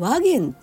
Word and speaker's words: 和顔: うところでなんとなくこう自分の中で改めて腹に落ちたというかそんな感じで和言愛語って和というのは平和の和和むですよね。で弦はあの顔なんです和元じゃなくてそれを和言って読む